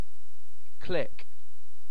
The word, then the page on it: click: British English